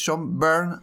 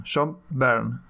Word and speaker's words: Byrne: Eh, el quien mejor pronuncia el mejor hispanohablante pronunciando el apellido del dibujante anglo-canadiense es Carlos Pacheco que aclara una y mil veces que es John Byrne como si fuera quemándose, como la canción conocidísima de, Deep Purple. Bueno, John Byrne.